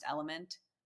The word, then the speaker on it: LMNT: And then I also like, a lot of my dietitian friends like LMNT, which is just pronounced element.